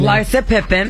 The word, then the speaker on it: Larsa Pippen: Larsa Pippen.